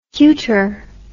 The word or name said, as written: cuter